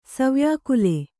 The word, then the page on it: ಸವ್ಯಾಕುಲ: savyākula